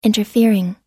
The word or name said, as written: interfering